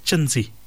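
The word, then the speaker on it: chintzy: The word that we were looking for today, Marana, is the word chinzi. The word chinzi. It's spelled C-H-I-N-T-Z-Y. C-H-I-N-T-Z-Y.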